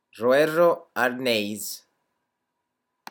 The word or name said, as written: roero arneis